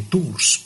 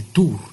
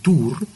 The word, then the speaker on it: Tours: No se dice de Tours, porque un Tours es un viaje, ¿no? San Martín de Tours, la ciudad de Tours, en Francia, vivía un soldado romano.